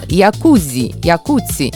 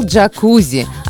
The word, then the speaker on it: джакузи: Итальянец Кандидо Якуци изобрел джакузи. А джакузи – это неправильное американское произношение этой итальянской фамилии, которая, однако, прочно укоренилась во многих языках мира.